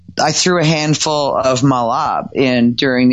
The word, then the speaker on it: mahlab: I mean, you know this, of course, but Malab, guys, and it's spelled M-A-H-L-A-B, is denatured cherry pits that have been crushed.